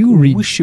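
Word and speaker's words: Uexküll: They say it like Oekskool.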